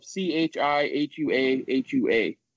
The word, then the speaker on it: Chihuahua: recognized as a soft white cheese available in braids, balls, or rounds, and or originates in the Mexican state of C H I H U A H U A.